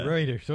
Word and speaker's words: Reuters: Reuters, okay. Yeah. In a June story. It's not pronounced how it's spelled. – I know. So, neither is Huawei.